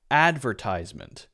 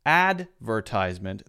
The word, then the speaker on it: advertisement: In American English, if you say that, advertisement, the first syllable is stressed. Advertisement. And it's a ties sound. You might hear in British English more often a stress on the second syllable. So adver, adver. And then instead of a ties-ment, maybe advertisement, advertisement, advertisement, tisement. So there's an S sound instead of a Z sound.